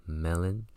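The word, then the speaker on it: Gmelin: Johann George Mellon, the G is silent, who spent 10 years investigating and researching in Siberia, published his observations at mid-century.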